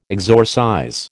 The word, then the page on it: exorcize: American English